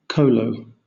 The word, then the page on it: kolo: British English